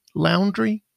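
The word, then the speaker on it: Laundrie: Loundry.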